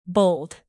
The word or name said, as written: bold